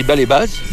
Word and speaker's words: Balébaz: Ça s'écrit Balébaz, mais en Savoie. Tu dis pas Servoz. – Comment? – Tu dis pas Chamonix. Tu dis Chamonix. Tu dis Baléba.